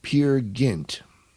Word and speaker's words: Peer Gynt: No, Henrik Ibsen, his 1867 play, Peer Gynt.